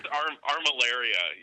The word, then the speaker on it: armillaria: That's armillaria, yeah. You pronounced it essentially right.